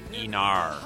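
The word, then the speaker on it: Aenar: And I'm sure they'll be saying that word a few times in this episode, but that is how it's pronounced, the Enar.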